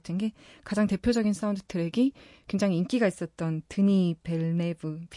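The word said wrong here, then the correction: Denis Villeneuve: 빌네브 어, 발음이 너무 어렵네요. 드니 빌네브 감독의 컨택트라는 영화.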